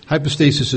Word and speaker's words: hypostasis: Hypostasis is spelled H-Y-P-O-S-T-A-S-I-S.